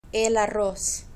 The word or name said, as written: el arroz